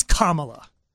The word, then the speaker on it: Kamala: By the way, it's Kamala. I'm not endorsing one candidate over the other, but this girl has been in politics a long time. This woman, this bee, say punctuation. What's that? – Comma. – Say la. – Yeah. – La. – Okay. That's your name.